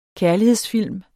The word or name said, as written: kærlighedsfilm